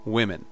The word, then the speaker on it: women: E dessa vez tem uma diferença na pronúncia. Woman, women.